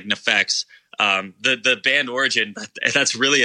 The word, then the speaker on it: NEFFEX: But it's called NEFFEX, right? – Yep, it's called NEFFEX.